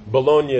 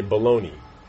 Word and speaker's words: Bologna: And just as a context, Bologna is how you say it in Italian. That's the place. In America, you say baloney.